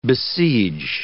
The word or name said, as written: besiege